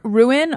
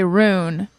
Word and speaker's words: ruin: Also, see, I said I put the L in there, but the word ruin, R-U-I-N, my brain wants to say rune. And I have to purposefully go. I have to make sure I say it as two syllables.